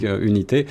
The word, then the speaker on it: UNT: C'est ça, donc euh, pour, euh, pour les auditeurs, ça s'écrit UNT, hein, et on dit unité.